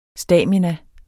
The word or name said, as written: stamina